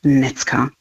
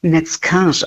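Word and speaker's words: Neckář: Ich muss dazu sagen, eigentlich wird er im Original Neckars ausgesprochen, kleines Häkchen über dem R, aber da muss ich noch ein bisschen üben. Heute würde ich es mal noch bei der eingedeutschten Version belassen.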